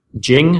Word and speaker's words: Jing: There's this product you have called Jing.